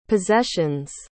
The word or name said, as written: possessions